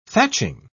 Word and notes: thatching: British English

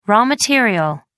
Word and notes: raw material: American English

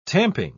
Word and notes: tamping: British English

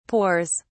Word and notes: pores: British English

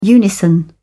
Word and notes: unison: British English

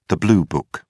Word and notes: blue-book: British English

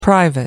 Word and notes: private: American English

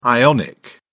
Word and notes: ion: American English

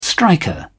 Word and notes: striker: British English

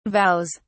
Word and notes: vows: British English

